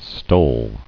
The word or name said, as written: Stull